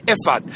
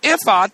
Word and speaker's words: ephod: Now the ephod, and it's in the English Bible, it's spelled E-P-H-O-D.